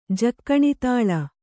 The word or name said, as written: ಜಕ್ಕಣಿತಾಳ